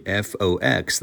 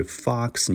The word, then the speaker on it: fox: 所以当你下次再讲到狐狸 f o x 的时候啊，不要读成 fox，对不对？fox news 不对哈，应该是 fox news，发的是 r 短音 r 的音，fox news，或者类似的我们还有 looking for a job。